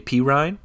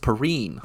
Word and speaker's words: Perine: So, fun fact about that guy, you call him uh, Samaje P. Ryan. It's actually pronounced Perine.